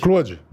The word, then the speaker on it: Claude: É, Claude. Claude. Igual o Claude Van Damme, só que eu sou mais Claude alto e mais forte.